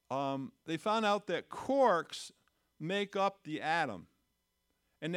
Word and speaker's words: quarks: And the smaller particles, they call them quarks, like a cork in a bottle, only spelled with a Q, okay?